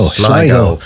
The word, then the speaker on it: Sligo: That is how you pronounce it, isn't it? It's not Sligo, Sligo. – Sligo.